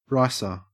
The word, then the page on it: ricer: Australian English